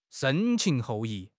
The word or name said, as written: 深情厚谊